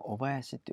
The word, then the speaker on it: 小林: で今の時点での職場というのは下半球の小林という、小林と書いて小林と読むんですけども、宝塚線の小林駅なんですね。